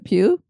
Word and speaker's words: Pugh: And honestly, the reason why I said it that way, and that probably is how you say it, is I actually had a friend in school, and that was her last name, was P-U-G-H, and it was Pugh.